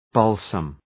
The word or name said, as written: balsam